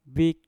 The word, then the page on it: bik: mpur